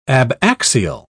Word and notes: abaxial: British English

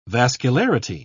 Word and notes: vascularity: British English

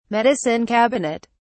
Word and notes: medicine cabinet: British English

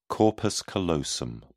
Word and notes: corpus callosum: British English

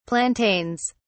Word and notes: plantains: British English

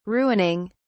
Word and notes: ruining: British English

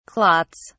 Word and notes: clots: British English

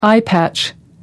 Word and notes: eyepatch: American English

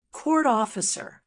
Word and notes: court officer: American English

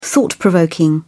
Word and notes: thought-provoking: British English

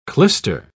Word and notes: clyster: British English

